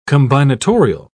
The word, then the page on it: combinatorial: British English